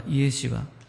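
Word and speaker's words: イ エ シュア (ישוע): ヘブル式の発音ではイエシュアというようです。英語ではもちろんおなじみのジーザス。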